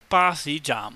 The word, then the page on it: basihjaahm: Cantonese